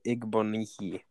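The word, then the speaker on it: Igbinoghene: All right, 30th pick, Noah Igbigno Dehi. – It's you didn't how do you say it right, Corey. It's hold on. I'm gonna ask really Siri. It's Noah Igbonihi.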